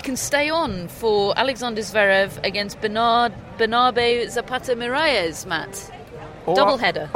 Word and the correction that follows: Miralles: At least Matt knows how to say his name. I butchered it yesterday. What's his name again? – Bernabe Zapata Miralles.